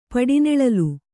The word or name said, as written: ಪಡಿನೆಳಲು